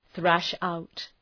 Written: thrash out

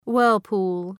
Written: whirlpool

